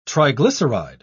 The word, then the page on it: triglyceride: British English